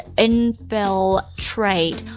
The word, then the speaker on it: infiltrate: 好，这个单词 infiltrate，注意一下这个 l 的发音呢， infiltrate。